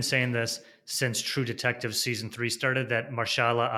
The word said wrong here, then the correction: Mahershala: Ali, am, am I pronouncing his name right? Mahershala Ali, I believe.